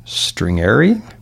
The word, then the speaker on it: stringere: The English word stress comes from the Latin word stringere.